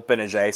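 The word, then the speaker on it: Gbinije: They are led by former Duke player Michael Benajay. Silent G will be back in Cameron.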